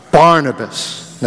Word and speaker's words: Barnabas: But Barnabas.